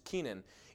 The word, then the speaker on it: Kenan: That would have been spelled with a Q in Hebrew if you're interested, but still pronounced Kenan.